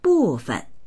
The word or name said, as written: bu4fen